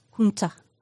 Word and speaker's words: junta: It's spelled J-U-N-T-A. Junta. – It's Spanish.